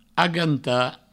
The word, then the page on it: agantar: aganta